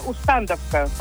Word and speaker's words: Усть-Тандовка: Подождите, озеро Тандова, деревня Усть-Тандовка, или как правильно? – Да. – Почему-то ударение плавает, получается. – А раньше Усть-Тандовка, правильно, произношение.